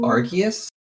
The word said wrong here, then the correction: Agrius: I'm sorry. It's Agrius.